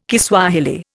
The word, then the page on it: Kiswahili: Indian